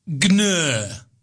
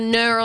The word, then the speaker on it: gnurr: Gnr. – Gnr? – G-N-U-R-R. – Or is that just gnr? – No. – Silent G.